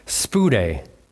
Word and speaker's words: spoudē: The word in the Greek, if I'm saying it right, it's pronounced spoudē. Spoudē, it kind of sounds similar to speedy.